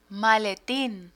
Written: maletin

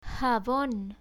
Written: jabon

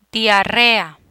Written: diarrea

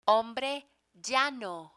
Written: hombre llano

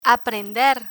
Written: aprender

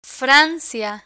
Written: francia

